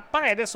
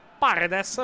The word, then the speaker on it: Paredes: Entra Paredes o Paredes, perché l'ho sentito chiamare più volte Paredes, quindi probabilmente la, la pronuncia la pronuncia è con l'accento sul, sulla prima.